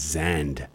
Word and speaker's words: Gsand: The G and the Z, the S put together, it pronounces Zand, G-S-A-N-D.